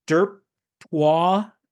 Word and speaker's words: Derptoise: The page was actually called Derptoise, spelled like D-E-R-P-T-O-I-S-E, like kind of like French, Derptoise, the Jew.